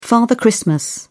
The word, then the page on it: father christmas: British English